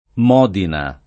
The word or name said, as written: Modina